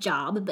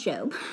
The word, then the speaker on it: Job: Okay, so the story of Job is Job, spelled like Job, but said Job.